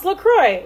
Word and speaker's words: LaCroix: Marcia just paused her story and drank some LaCroix. LaCroix, sorry. Bad pronunciation there. – Well, who was it who explained it? Oh, on their website, it says, it's pronounced LaCroix, like, enjoy. Rhymes with enjoy.